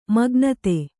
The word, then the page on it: ಮೇಘನಾದ: mēgha nāda